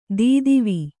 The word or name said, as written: ಧತ್ವ